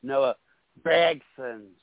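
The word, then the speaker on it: Gragson: I've heard Dale Jr. pronounce Gregson, Noah Gregson. Obviously, we've been pronouncing his name wrong this entire time, but it's Noah Gregson.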